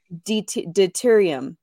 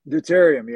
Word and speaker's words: deuterium: Deuterium. – Deuterium. – Deuterium, yeah.